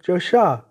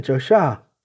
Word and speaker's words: Joshah: Somewhat recently it was found that the true meaning of Christ's name could acceptably be in the form of Joshah, meaning Jehovah is salvation, with Joe representing God's name and Shah indicating salvation. Again, Joshah. With the accent preferred on the last syllable,